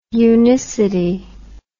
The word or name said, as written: unicity